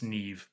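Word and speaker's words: Niamh: It's phonetically, it would be like Nim, but it's Neve because she's Irish.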